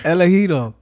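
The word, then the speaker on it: Elegido: Did I say it right? yeah. Elegito. It's, it's Spanish for uh, for chosen one.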